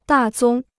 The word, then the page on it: 大宗: dà zōng